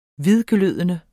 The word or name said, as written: hvidglødende